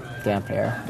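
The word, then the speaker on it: Damphair: Dampfer. – Dampfer.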